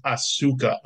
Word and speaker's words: Asuka: Her name is pronounced Asuka.